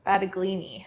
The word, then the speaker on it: Battaglini: Battaglini. – Battaglini. – Yeah, I think the Italian way is a little different, but really speak Italian, so.